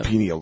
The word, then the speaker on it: pineal: Plus it calcifies your penile. How do you say that? – Pineal gland. – Pineal gland, that's it.